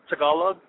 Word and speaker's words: Tagalog: I mean, were you speaking, I think it's called, if I'm saying this correctly, is it called Tagalog in the Philippines? Were you speaking here? – It's actually called Tagalog, yes.